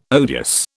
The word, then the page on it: odious: British English